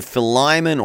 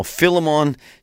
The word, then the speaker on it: Philemon: We've been looking at the book of Philemon, or Philemon, Shell, depending on how you want to pronounce it.